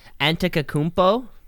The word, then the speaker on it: Antetokounmpo: Pronounce his last name? – Antetokounmpo. – Nice, nailed it.